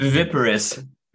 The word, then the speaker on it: viviparous: It's called, I'm probably mispronouncing it. V-I-V-I-P-A-R-I, viviparry, I think. – Viviparous, viviparry.